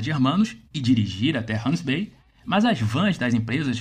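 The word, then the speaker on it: Gansbaai: Tudo acontece na cidade ao lado, Hans Bay, que se escreve Gans Bay com G de Geraldo, mas essa letra tem som de R em africâns.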